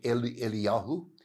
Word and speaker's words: Elijah: Now this word Elijah is important. Could also be pronounced Eliyahu.